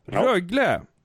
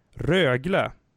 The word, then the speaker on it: Rögle: Rögle! Ja. Så där uttalar man det faktiskt inte, har jag fått lära mig av Johan Svensson och Sanne Lindström, mina kära kollegor och vänner. Rögle, Växjö, Luleå, Skellefteå, Leksand, Frölunda, Örebro, Färjestad, Malmö, Djurgården, Brynäs, Oskarshamn, HV71, LHC.